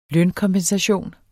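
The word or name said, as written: lønkompensation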